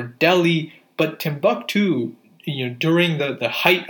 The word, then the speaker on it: Timbuktu: I think I pronounced it right. Timbuktu, it's spelled Timbuktu.